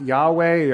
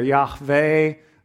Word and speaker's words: Yahweh: Sometimes pronounced Yahweh or Yahweh. Jehovah is another way that that's pronounced.